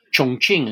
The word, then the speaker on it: Chongqing: Remember that during the war against Japan, about which we've spoken, that long, bitter conflict, the nationalist capital was in Chongqing, Chongqing as it's pronounced correctly, way up the Yangtze.